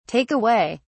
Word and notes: take away: British English

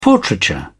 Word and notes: portraiture: British English

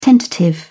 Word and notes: tentative: British English

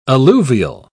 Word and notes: alluvial: British English

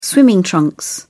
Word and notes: swimming trunks: British English

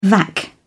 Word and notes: vac: British English

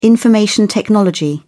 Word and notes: information technology: British English

